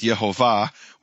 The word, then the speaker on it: Yehovah: Instead of Shvach Olam Kamatz, which is pronounced Yehovah, we found in 2 manuscripts, 1 time in each, Shvach Olam Patach, which is pronounced Yehovah. and